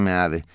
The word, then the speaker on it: Meade: MID, el apellido es español y se pronuncia Meade.